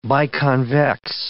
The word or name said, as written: biconvex